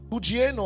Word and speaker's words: hugiaino: The Greek word for sound there is hugieno. I'll spell it H-U-G-I-A-I-N-O. Okay? I'll spell it one more time. H-U-G-I-A-I-N-O.